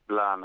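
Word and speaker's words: Blain: It's Blan, I think the race of French pronounces it. It's B-L-A-I-N.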